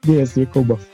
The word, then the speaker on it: Diyaz Yakubov: Say your last name, Diaz. I can't say. – Diaz Yakubova.